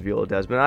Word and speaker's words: Viola Desmond: I'm pretty sure it's Viola Desmond.